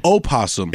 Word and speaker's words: opossum: It's pronounced opossum is there, the actual traditional spelling and pronunciation of the animal possum.